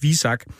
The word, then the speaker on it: VISAK: der fortalte mig, at det udtales VISAK.